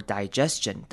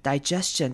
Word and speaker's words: digestion: Free fall, free fall is good for digestion. Digestion 这个字也是今天要特别注意的字。首先看前面 digestion 的 i，我们要念成长母音 i 的声音。 Digestion。这边 GE 的那个 E 是短母音 E，的声音，所以是 digestion。